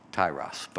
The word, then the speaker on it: Tyre: and they land at this town that I've always called Tyre, T-Y-R-E, but its actual pronunciation is Tyros, but I'm not going to call it Tyros because it doesn't make sense.